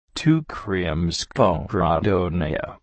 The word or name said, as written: Retable